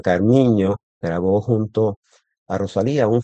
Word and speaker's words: Carminho: Carminho, que la ñ en portugués se escribe NH, Carminho. Carminho, en entrevistas en español, que habla muy buen español, ella menciona la importancia que tiene en el fado la letra.